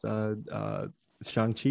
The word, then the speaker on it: Shang-Chi: So I'm bringing it up because two out of three of us went and watched Shang-Chi. I think I pronounced it right because he definitely made you pronounce it right throughout the movie.